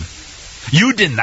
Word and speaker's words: Juden: How do you pronounce it in German? Juden, that's right, thank you.